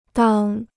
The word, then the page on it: 当: dàng